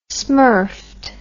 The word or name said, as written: smurfed